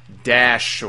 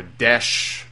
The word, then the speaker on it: Daesh: You know, people have started referring to ISIS as Daesh or Desh, depending on how you choose to pronounce it.